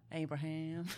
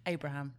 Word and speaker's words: Abraham: Abraham. Yeah, no, you had it right. Ignore how I just said it. Just Abraham.